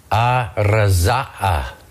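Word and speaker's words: araza'a (ארזא): The word we see there for, or we see in English as mystery, in the Aramaic is araza'a.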